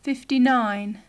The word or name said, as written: fifty-nine